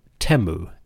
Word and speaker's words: Atum: We call him Atum, but the Egyptian pronunciation might be closer to Temu, aka the one who is complete.